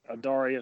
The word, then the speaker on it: Adaria: And by that same token, I might even consider, I believe you pronounce this, Adaria. – I believe that's right. – Adaria, who's coming into form, it seems like, at the end of her four-year-old campaign with some really nice Group 1 efforts.